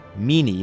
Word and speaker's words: Minnie: It's the story of Minnie, Meanie in the Italian pronunciation, a tough but tender woman who is loved by the California gold miners to whom she is a devoted mother figure.